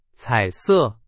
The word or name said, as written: 彩色